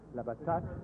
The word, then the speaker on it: Labatut: No, but the point is that, my name is Labatu. – Labatu. – L-A-B-A-T-U-T. – Yeah. – And actually with the, it is difficult, the U is very difficult, so it is pronounced Labatat or Labatut.